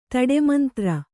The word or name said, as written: ತಡೆಮಂತ್ರ